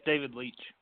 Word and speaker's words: David Leitch: How do you pronounce it? – Yeah, it's David Leitch.